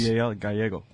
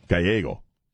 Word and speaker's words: Gallego: Gallego. – Gallego. Okay, I'm just on CD, on disc number one with the Spanish thing, okay? Ruben Gallego.